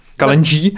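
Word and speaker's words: Kalenji: Já třeba u písničky, no, jak se to vyslovuje, Kaleni? Kaleni? Kaleni? Kaleni? ne, no, no. Kalenží.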